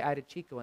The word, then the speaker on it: Iadicicco: And my last name was on two lines, Dudici Coco, like hot chocolate, C-O-C-O-A. Like so, I don't know, somebody heard me say Iadicicco, and they decided to spell it Dudici space Coco.